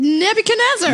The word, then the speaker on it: Nebuchadnezzar: How do you say it? – Nebuchadnezzar. – Nebuchadnezzar. Nebuchadnezzar. I can say, okay, Nebuchadnezzar. Is that better? – Nebuchadnezzar. – Thank you.